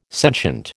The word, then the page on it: sentient: American English